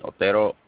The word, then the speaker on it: Otero: My name is Chester, spelled J-E-S-T-E-R, last name Otero, O-T-E-R-O.